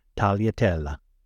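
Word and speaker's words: tagliatelle: It is pronounced Tagliatella.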